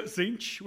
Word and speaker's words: Tzeentch: Do you how do you pronounce do you pronounce the T? – That's always Tzeentch because I'm not an absolute lunatic. – Exactly. – So, so, I'm I'm not a psychopath, so I prefer to pronounce the T as silent because obviously that's the natural way to pronounce a T. – Yeah, yeah. Unless so, you're from Lancashire, which I am, in which case when we're, other than the, you put t.